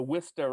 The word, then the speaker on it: Worcester: But all of a sudden, we're talking about the Worcester Red Sox a little bit more with Chris today, which that's how you say it.